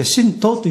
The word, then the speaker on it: 神道: で、我々が日常の生活の中で目に見えないその神様との関わり、はい、こちらも神の道と書いて神道、これは道じゃなくて神道というふうに言葉で呼んでおります。 – はい、はい、で、まあ、いわゆる代表的なのはこう神社とかね、お参りしたり神様と関わりあることを、まあ、神道というんですけれども、